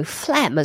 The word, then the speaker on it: phlegm: Also, phlegm has got a really unusual spelling. If I'm correct, it's spelled P H L E G M, when really it should just be spelled F L E M.